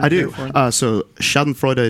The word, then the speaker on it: Schadenfreude: It's pronounced National Traja? – No. – Oh. – It's pronounced Schadenfreude. It's German.